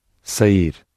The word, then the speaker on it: sair: Sair. – With verbs on both these tracks, the final syllable is stressed because it ends in an R.